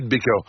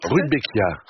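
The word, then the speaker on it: Rudbeckia: Faut-il couper les fleurs fanées des rubéquias à la fin de l'automne? – Les rubéquias, je vois que vous connaissez cette plante, ça se sent tout de suite.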